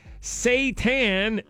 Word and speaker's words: seitan: Palsy tweets here, the chicken stuff you're talking about is pronounced Seitan, and it's terrible.